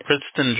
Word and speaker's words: Princeton: Oh, okay, no, es que dijiste Princeton Drive, muy correcto, y mucha gente que no habla inglés no puede pronunciar esa calle.